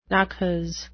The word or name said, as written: naches